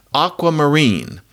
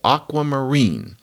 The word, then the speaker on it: aquamarine: I'm talking about if I say aquamarine, the primary stress is on that last syllable, aquamarine.